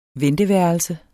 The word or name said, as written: vente